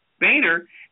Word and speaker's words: Boehner: And then Boehner, how you pronounce it? – Boehner.